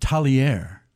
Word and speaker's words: Tagliari: How did you attract Tagliari to come there? I mean, that's a great one. – So let me correct you because they corrected me. It's pronounced Talier. – Talier? – Talier.